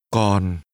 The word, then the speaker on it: Còn: Còn. Còn. – Still is pronounced with a falling tone.